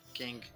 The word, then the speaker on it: Kang: E com o Reino Quântico ganhando espaço nessa história, a gente vai ver o vilão Kang. Acho que é assim que pronuncia, né? A gente é. falou tanto esse nome mesmo sendo assim, né, decorei. – Kang.